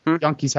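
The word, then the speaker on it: Janke: Inker is Dennis Yonke. I don't completely know how to pronounce that. Colorist? – Yonke's how I've heard it pronounced.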